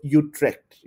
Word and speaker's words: Utrecht: So, Utrecht.